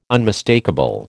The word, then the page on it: unmistakable: American English